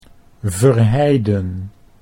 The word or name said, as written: Verheiden